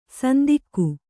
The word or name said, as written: ಸಂದೇಗ